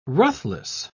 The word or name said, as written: ruthless